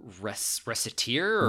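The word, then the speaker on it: Recettear: And I think my first exposure to that, and I thought I enjoyed, did you ever play, I always pronounce this wrong, Resetia, I think is the name, on Steam? – Is it reciteer? – Reciteer, yes. Yeah, it's a...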